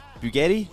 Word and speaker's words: Bugatti: Earlier, bro, I saw a Bugatti. Is that how you say it? Bugatti? – Yeah.